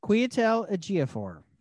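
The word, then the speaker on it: Chiwetel Ejiofor: Kwiatel Ejiofor.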